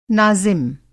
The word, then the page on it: नाज़िम: Hindi